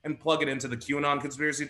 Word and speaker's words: QAnon: I know it's pronounced QAnon, but